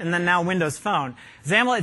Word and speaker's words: XAML: Now, XAML, X-A-M-L, we pronounce it XAML.